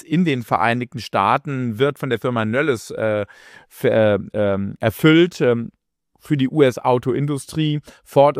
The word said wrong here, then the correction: Novelis: Novelis heißt das Unternehmen, hier nochmal korrekt ausgesprochen.